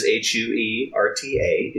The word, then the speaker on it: Huerta: My last name is Huerta.